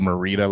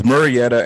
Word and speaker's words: Murrieta: You keep slaughtering the city he's from. You said Murrieta last time. You got to laugh out of that. – I don't know how to pronounce shit. All right. – So it's Murrieta.